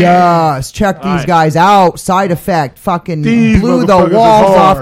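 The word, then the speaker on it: Psyde Affect: Side Effect.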